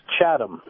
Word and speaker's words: Chatham: Actually, it's pronounced Chatham.